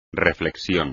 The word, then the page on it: reflexión: espagnol